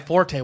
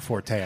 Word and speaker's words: forte: That is, other than fastball references, that is my forte with this podcast. Just oh, is it? – It's pronounced fort, by the way. – I didn't even realize that. – It's not forte.